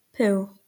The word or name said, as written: pooh